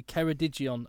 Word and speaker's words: Ceredigion: A lynx has escaped from a wildlife park in Ceredigion.